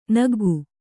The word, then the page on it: ನಗ್ಗು: naggu